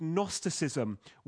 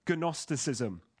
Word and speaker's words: Gnosticism: It had been mixed up with Jewish legalism, with elements of what Bible teachers have called Gnosticism, with a silent G, as in gnome, Gnosticism.